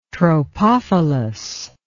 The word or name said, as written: tropophilous